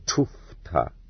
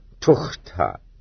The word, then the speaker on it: tufta: I have been reproached with spelling this word incorrectly, and told that it should be written as it is correctly pronounced in thieves' jargon, tufta. For tukhta is the peasant's assimilation of it, just like kviodor for Fyodor.